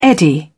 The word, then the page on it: eddy: British English